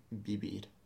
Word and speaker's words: vivir: It's quite a soft B. So vivir.